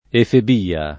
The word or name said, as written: efebia